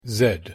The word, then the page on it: z: English